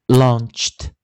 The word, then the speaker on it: launched: 所以尾音的 e d 还要念轻音念 t launched。